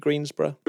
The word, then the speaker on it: Greensboro: UNC Greensboro? – You are.